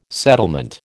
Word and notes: settlement: American English

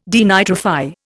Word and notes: denitrify: Indian

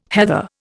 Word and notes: heather: Indian

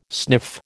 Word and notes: sniff: American English

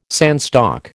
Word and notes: sandstock: American English

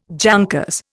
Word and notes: jankers: Indian

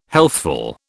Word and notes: healthful: British English

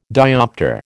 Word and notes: dioptre: American English

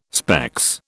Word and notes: specs: British English

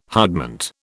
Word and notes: hutment: British English

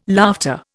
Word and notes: laughter: Indian

